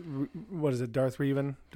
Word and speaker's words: Revan: R-E-V-A-N is a Reeven. I've never Reeven.